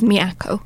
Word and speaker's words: Miyako: was that Miyako? – So where I ended up long-term was Miyako. – Mm-hmm. So I did get the pronunciation reasonably right.